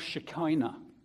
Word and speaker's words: Shekinah: It's called Shekinah. Shekinah. I always remember it because it rhymes with Shina. Shina, Shekinah. Yeah, up to this point, as we said, the usual place for God's glory was in the temple or the tabernacle before that.